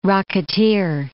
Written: rocketeer

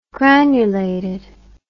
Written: granulated

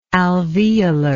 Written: alveolarly

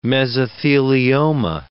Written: mesothelioma

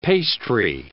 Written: pastry

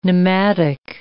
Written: nematic

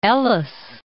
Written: Ellice Islands

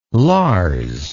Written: Lars

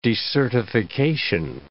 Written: decertification

